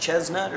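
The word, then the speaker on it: Chesnutt: Is it Chestnut or Chestnut? – Chess with an S.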